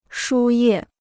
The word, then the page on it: 输液: shū yè